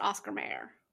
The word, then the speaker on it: Oscar Mayer: Like, yes, it's Oscar, and the Mayer part is spelled M-E-Y-E-R. Or, if you're in this universe, M-A-Y-E-R. Is it Oscar Mayer or Mayer? And it's definitely Mayer.